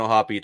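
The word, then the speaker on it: O'Hoppe: It is, so you need to pronounce the E, okay? I wasn't sure if the E was silent. So Logan Ohapi,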